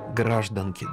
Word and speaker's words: гражданки: А вот с таким ударением «гражданки», да, вроде как уравнивались представители мужского и женского пола, имеющие гражданство СССР.